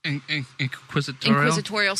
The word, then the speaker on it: Inquisitorial: And the Inquisitional squad, or when Inquisitorial, how do you say that word? – Inquisitorial squad, yes.